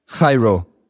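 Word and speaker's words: chairo (χαίρω): So it'd be like chairo.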